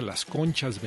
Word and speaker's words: Les Conches: Se trata de Les Conches Velázquez, que según entiendo, vi ahí algunos videos con entrevistas, creo que se pronuncia Las Conchas Velázquez, pero se escribe Les Conches.